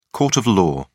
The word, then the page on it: court of law: British English